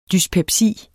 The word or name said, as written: dyspepsi